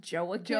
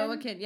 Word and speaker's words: Joaquin: You know his name is pronounced Joaquin? – Joaquin, yeah, we've talked about this on a previous podcast.